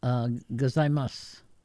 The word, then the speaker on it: gozaimasu: In Japan, in Tokyo anyway, they don't pronounce the U. – Oh. – So, gozaimasu. It's kind of, that's a Tokyo accent. They slur a lot of the vowels, like in S-H-I, it's they don't pronounce the I.